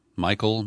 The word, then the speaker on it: Michel: Also in Los Angeles, the police chief, who spells his name M-I-C-H-E-L, Chief Michael Moore, I guess the way that's how he pronounces it, he addressed the media and offered this kind of bizarre equivalency as he threatens to crack down on looters.